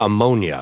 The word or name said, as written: ammonia